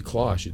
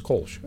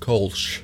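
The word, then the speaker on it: Kölsch: So it wouldn't be Klosh, it's Kolsch. – It's a Klosh. – As you know, I'm not good at spelling. – So. True. – For the listener at home, it's pronounced Kolsch.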